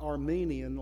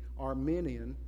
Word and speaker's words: Arminian: In fact, the whole Arminian school, it's not Armenian like the country, it's spelled with an I, not an E, Arminian,